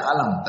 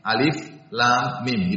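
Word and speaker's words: alif lam mim (الم): Makanya dibacanya bukan alif seperti alif lam mim, tidak dibaca alam, tapi alif lam mim, dibacanya per huruf.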